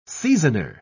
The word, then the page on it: seasoner: British English